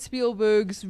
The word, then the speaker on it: Spielberg: Spielberg. As in Afrikaans spiel, as in play. Berg. – Mountain.